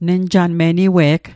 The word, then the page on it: niwe'g: Mi'gmaq